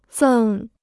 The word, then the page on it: 赠: zèng